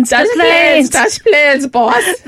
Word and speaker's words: Des Plaines: Des Plaines? – Yes.